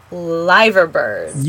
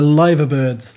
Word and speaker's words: Liverbirds: So they've got two cathedrals, two soccer teams, and then that famous symbol that stands over the city, which I have pronounced wrong, but the two Liverbirds. – You got it. That's right. The Liverbirds.